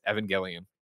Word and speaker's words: Evangelion: Lind announced the news via his Twitter in a tongue-in-cheek video riffing on the next episode bookends of Neon Genesis Evangelion. I screwed up, right? Evangelion. What are you nerds? How do you pronounce it? – You know, you got it the first time.